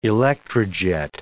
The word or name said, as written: electrojet